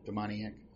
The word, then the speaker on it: demoniac: So here we're going to get into what I'll call, after we had finished with the demoniac, is that how you pronounce it? Demoniac? Yeah. Okay, yeah, that sounds good.